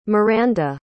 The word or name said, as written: maranda